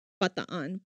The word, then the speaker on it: Bataan: I'll start off by Pearl Harbor and the Bataan Death March, which is actually known as Bataan Death March. Like in the Philippines, it's actually Bataan, but we pronounce it Bataan.